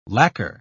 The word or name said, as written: lacquer